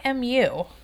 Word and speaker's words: imu: She wants to build one of those ovens, but I don't think it's pronounced emu. – We just call it the pit. I mean, we're a bunch of yinzers in Hawaii and Guam. – It's IMU. It's the underground oven.